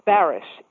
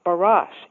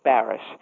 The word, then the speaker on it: Barish: The Times spelled it L-Y-N-N-E, and so it was. My last name is pronounced Barish, even though it looks like Barash. In fact, when I first met my husband, I thought it was Barash, but soon found out it was Barish.